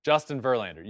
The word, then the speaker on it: Verlander: Coming in number 7, I hope I'm not mispronouncing this, Justin Verlander? – That's right, number 7, Justin Verlander.